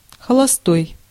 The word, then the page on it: холостой: [xəɫɐsˈtoj]